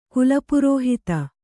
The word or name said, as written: ಕುಲಪುರೋಹಿತ